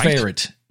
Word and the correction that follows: Ferrite: What's the name? – Ferrite. – Ferrite.